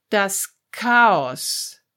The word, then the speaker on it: Chaos: There are some more exceptions in words that we adopted from other languages into German. It's not many words, and it's best to learn them together with their pronunciation. So, some words from Greek. Das Chaos. Der Charakter.